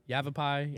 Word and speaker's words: Yavapai: Yavapai. – Yavapai. – It's Y-A-V-A-P-A-I.